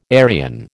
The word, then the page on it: Aryan: American English